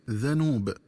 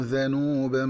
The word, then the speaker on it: ذنوب: ينبغي التنبه للنطق الصحيح لكلمة ذنوب، فهي تقرأ بفتح الذال هكذا ذنوب. – فإن للذين ظلموا ذنوبا مثل ذنوب أصحابهم، والذنوب النصيب من العذاب، ومن الخطأ قراءته بضم الذال هكذا ذنوب.